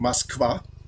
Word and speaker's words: Moskwa: Wir sagen nicht Moskwa, wie es eigentlich heißt, sondern Moskau.